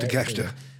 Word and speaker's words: Takeshita: My point is when Don Callis and Takashita, whatever. First of all. I like what they're doing with, how you say it? Takeshita, right? – Takeshita.